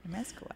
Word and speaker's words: Amezcua: we actually sat down with Mr. Carlos Amezcua. Did I say that right? – That's right. Amezcua.